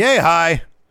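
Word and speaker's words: Yehi: How do you pronounce his last name? – Yay, hi. – That's right.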